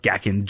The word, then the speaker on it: Gakken: I guess it's pronounced Gakken, G-A-K-K-E-N, used to